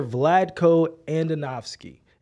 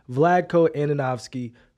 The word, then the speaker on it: Vlatko Andonovski: And my question to both of you is, should we fire Vlatko Andonovski? And I actually learned how to say his name because I needed to say it on today's show. So Vlatko Andonovski, should we fire him, Ro, yes or no? – Yes.